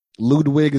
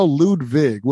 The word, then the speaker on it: Ludwig: Ludwig is now Ludwig with a V.